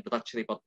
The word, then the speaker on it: Drudge Report: tulisannya Drudge ya, bacanya Drudge Report gitu nah kemudian ditayangkan di e,